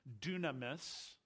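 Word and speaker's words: dunamis (δύναμις): I'll pronounce it for you. It's dunamis, and dunamis comes from the idea of dynamo or dynamite.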